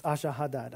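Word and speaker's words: Esarhaddon: It's Ashahadad. I don't know how else to say it, but that's how you'd pronounce it if you were saying it the proper way.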